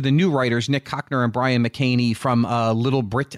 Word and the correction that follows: Little Britain: I always say it wrong. Little Britain.